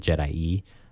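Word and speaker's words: Je'daii: We often call it the Jedi. Just based on how it's spelled. But I think, Jordan, according to your research... – it's hard to find. I couldn't find any official John Ostrander like confirmations. Of how to pronounce it. I think from the best, and I agree with this interpretation, is there's two I's at the end. That would lead to like an E sounding at the end, Jedi E, and that kind of does make sense.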